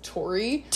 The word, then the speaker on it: Torey: And it's so funny because, like, his first name is spelled T-O-R-E-Y, so it's Tori. – Tori.